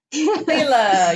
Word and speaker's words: Lila: Lila, yes.